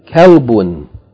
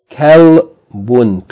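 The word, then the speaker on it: Kalbun (كلب): Hadha kalbun. Kalbun. Two syllables. Kalbun.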